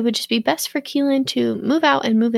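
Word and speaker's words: Kelan: They took in Carolyn's teenage nephew, Keelan, I think is how you say it. K-E-L-A-N, I think it's Keelan.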